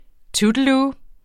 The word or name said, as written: tuttelu